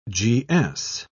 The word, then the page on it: gs: British English